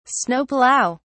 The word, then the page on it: snow plow: English; British English